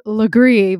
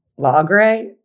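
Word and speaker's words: Lagree: Apparently the correct way is Legree, but you, what were you saying? – Lagre? – Lagre. Okay. – Lagre. You know what? Regardless of how you pronounce it, that is a hard workout.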